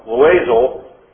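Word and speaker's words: Loizeaux: Darby, Malachi Taylor, and Paul, how do you say this guy's name? – Loisel.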